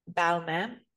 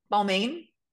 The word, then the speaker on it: Balmain: And my videos that have performed the best are usually from brand, usually when it relates to Balmain, the brand Balmain, is how you say it, and not how do most people say it? Balmain. – Balmain. – Balmain. It's Balmain.